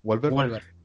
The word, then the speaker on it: Wahlberg: Wahlberg.